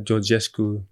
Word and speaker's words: Georgescu: Maar Georgescu.